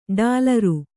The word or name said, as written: ಡಾಲರು